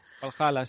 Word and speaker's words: Valhalla: Y es el que ha salido el tráiler de la historia de Assassin's Creed Valhalla, no sé si se pronuncia así. – Valhalla, sí.